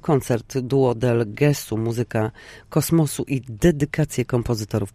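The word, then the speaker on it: Gesù: Gesu, proszę zwrócić uwagę też na akcent.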